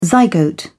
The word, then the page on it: zygote: British English